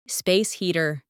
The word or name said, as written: space heater